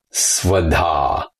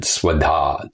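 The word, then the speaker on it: svadhā: Svoboda, S-V-A-D-H-A, long A at the end, Svoboda.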